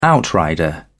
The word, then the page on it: outrider: British English